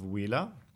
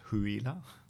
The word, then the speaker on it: Huila: Um, it's in the south of Colombia, so in the state of Huila. – Mm -hmm. – That's spelled with an H, Huila. Huila.